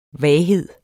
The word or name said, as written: vaghed